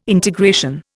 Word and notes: integration: Indian

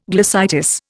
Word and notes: glossitis: Indian

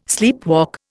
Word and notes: sleepwalk: Indian

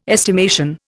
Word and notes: estimation: Indian

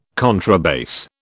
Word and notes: contrabass: British English

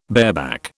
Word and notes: bareback: British English

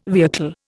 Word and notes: vehicle: Indian

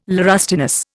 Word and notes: laurustinus: Indian

